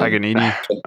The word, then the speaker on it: Paganini: Number two would be Sam Pagiani, or however you say his name. – Paganini. – Sam Panini. – It's like Panini, dude.